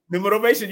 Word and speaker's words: Numerovation: Numerovation. Did I say it right? – Numerovation. You said it right, girl.